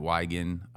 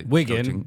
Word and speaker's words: Wigan: Now he's at Wigan. – Wigan. But they're going to correct you, bro. – Yeah. Wigan. Sorry.